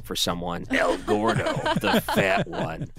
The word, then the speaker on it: Gordo: Is it Gordo? Like you're gorging yourself? – Yeah. – You're fat? – Well, G-O-R-D-O, El Gordo, the fat one.